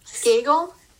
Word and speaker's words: Skagel: Rhymes with bagel. Skagel.